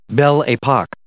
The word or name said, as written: belle epoque